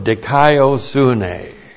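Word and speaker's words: Dikaio sune (δικαιοσύνη): And the preposition ace plus the accusative of dikaio sune. In the book of Romans, you could say this summarizes the book of Romans. D-I-K-A-I-O-S-U-N, long E. Dikaio sune, righteousness.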